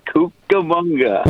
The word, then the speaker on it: Cucamonga: Is that how do I pronounce it? – That's the way you pronounce it, Cucamonga.